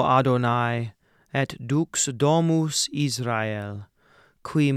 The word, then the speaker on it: Adonai: When we speak it, we say Adonai, but in this chant, we're going to break it up into one extra syllable at the end. Adonai, Adonai.